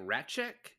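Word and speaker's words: Rasczak: I, they they pronounce it like Ratchek.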